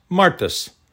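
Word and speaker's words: martis (μάρτυς): The Greek word for witness is muartis, pronounced martis, from which we get the English word martyr.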